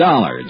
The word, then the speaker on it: Dollar: It's Dollar, Johnny Dollar. – Dollar, huh? – Yeah, rhymes with collar.